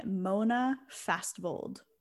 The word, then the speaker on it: Fastvold: And it's directed by Mona Fastvold, which mm-hmm. I believe I'm saying that right. – It sounds right to me.